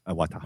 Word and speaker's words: Iwata: Addressing concerns that there may be possible health consequences with 3D gaming, Nintendo President Satoru Iwata, however you say it, I never say it right, so that's Iwata.